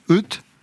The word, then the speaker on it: Oete: Euth, ça s'écrit comme poète, mais sans le P. On prononce Euth c'est une véritable construction, ce pseudo.